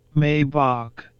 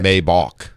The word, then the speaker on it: Maybach: Maybach. – See, that's how you pronounced it. – Maybach. I think that's correct, actually. – I think so, too. I think the other two videos are completely wrong.